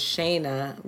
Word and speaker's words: Shayna: But I don't know if that's, you know, a black or white thing or more so just because my name is said as Shayna when usually that's spelled with one N and not two.